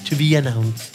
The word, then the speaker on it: To Be Announced: Y en el 2019, Bayonetta 3 sale con un TBA, To Be Announced.